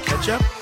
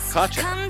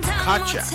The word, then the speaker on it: Kotchap: Ketchup? – It looks like ketchup. Kochap. – Kochap. – Like it's straight up. – Kochap. Spell it. – K-O-T-C-H-A-P.